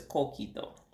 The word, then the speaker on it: coquito: I think I might go back to that co- conquito or whatever it's called. Coquito. Yeah, that. Say it right now.